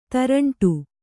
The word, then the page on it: ತೇರಂಟಿ: tēraṇṭi